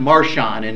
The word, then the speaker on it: Marcion: Well, in the middle of the second century, there was this guy called Marcion, or usually pronounced Marcion in English.